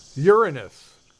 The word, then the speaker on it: Uranus: Uranus. Notice how I pronounced it correctly.